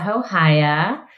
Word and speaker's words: Hohaia: Hi, my name is Marisa Hohaia.